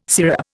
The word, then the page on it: sirup: Indian